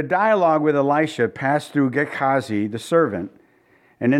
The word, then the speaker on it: Gehazi: And he said to Gehazi, that's how you, Gehazi is how my Bible app pronounced it, Gehazi.